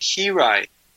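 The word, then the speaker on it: Hiraeth: Yeah, it's you pronounce it Hirai. – Hirai. Okay. – Hirai. And it's a Welsh word.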